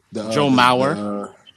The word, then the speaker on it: Mauer: So we're going to give a congratulations out to Adrian Beltran, Joe Mara. Beltray? Okay. – Yeah. – Joe Mara. I said his name right? – Joe Mauer.